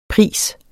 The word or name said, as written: pris